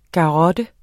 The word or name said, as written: garrotte